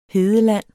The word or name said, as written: hedeland